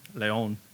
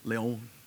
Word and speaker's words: León: Is it like Leon? – Yeah, Leon with the accent over the O. – León. – León.